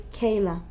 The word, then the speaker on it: bkaila: For Rosh Hashanah, I am used to do like, it's funny, it's called pkela. It's a typical Sephardic Tunisian meal. – How do you spell that? – Okay, B-K-A-I-L-A, but you don't pronounce the B. You say kela.